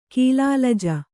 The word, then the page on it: ಕೀಲಾಲಜ: kīlālaja